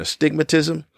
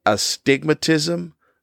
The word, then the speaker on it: astigmatism: When I was younger, I wore glasses for an astigmatism. which is a weird word because I believe it's always mispronounced. It's an astigmatism, not a stigmatism.